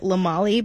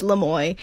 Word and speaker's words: Lamoille: And for the longest time, I was calling it Lamali because it's spelled L-A, capital L-A, and then capital Molly. So then I was like, okay, cool, we're going to Lamali. And in fact, no, it's Lamoille, which is a very nice hiking trail, I will say.